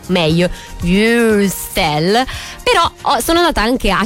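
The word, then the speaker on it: Würstel: Anche io nello scorso intervento ho detto Wüstel mille volte, si direbbe Wüstel, quindi oggi leviamo un po' di dubbi su come si scrive questa parolina in tutte le nostre liste della spesa.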